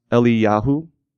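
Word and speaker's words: Eliyahu (אליהו): Elijah's name in Hebrew is actually pronounced Eliyahu, okay, which somehow sounds dorkier in the original language than it does in ours.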